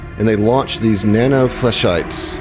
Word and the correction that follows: flechettes: Am I saying that right? – Flechette. – Flechettes. Nano flechettes.